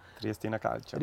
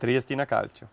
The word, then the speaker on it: Triestina Calcio: Triestina Calcio. – Richtig ausgesprochen? Sí. Wow, sag das nochmal, das hat sich super angehört. – Triestina Calcio.